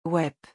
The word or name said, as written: ouaip